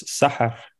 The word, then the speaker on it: Sahar: Well, I'm I'm not a, a native Arabic speaker either, so uh, it, uh, I think it's called you, you pronounce it as Sahar.